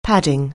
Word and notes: padding: anglais

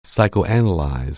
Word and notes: psychoanalyze: anglais

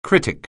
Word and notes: critic: anglais

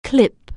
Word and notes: clip: anglais

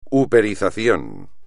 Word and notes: uperización: espagnol

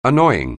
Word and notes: annoying: anglais

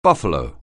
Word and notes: buffalo: anglais

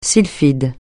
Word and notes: sylphide: francais

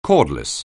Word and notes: cordless: anglais

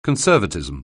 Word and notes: Conservatism: anglais

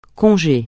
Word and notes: congé: francais

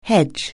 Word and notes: hedge: anglais

